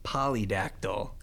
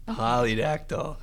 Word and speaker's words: polydactyl: So is it a pterodactyl hand? Did I say that correctly? – It is technically a, let's see here, polydactyl. Polydactyl.